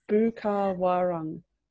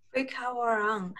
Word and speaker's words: Bukawarang: How do you say Bukawarang? Say it sweet. Ooh, ah, honey, yum. – Oh, and it kind of rhymes. – Bukawarang. – Bukawarang.